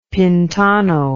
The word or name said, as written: pintano